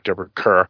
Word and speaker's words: Deborah Kerr: Deborah Kerr.